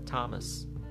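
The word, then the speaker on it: Thomas: آنان میگویند تامس تامس اصلا دقت بکنید چقدر تفاوت هستش آنچه میتوان گفت تامس تامس حالا توماس حداقل مثلا میشد بگیم توماس حالا همونطور که گفتم این در زبانهای مختلف تلفظهای مختلف داره ممکنه تو بعضی از زبانهای اروپایی بگن توماس یا مثلا توماش یا توماش همه اینا هست فکر کنم مجاری ها شه میگن بر در انگلیسی تامس هست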